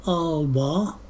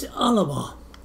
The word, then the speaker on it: Alba: And it wasn't till, maybe a year or so ago that I reckoned the proper pronunciation, that is the Gaelic pronunciation, isn't Alba, it's Alaba.